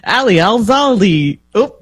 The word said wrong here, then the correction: Zaidi: There's no L in there. No. It's Ali Al-Zaidi, I think, is what it is.